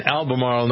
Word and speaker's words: Albemarle: Jeannie is in Albemarle, North Carolina. I always wonder if I'm pronouncing that correctly. Did I do it okay, Jeannie? – Yeah, you did great.